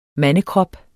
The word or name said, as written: mandekrop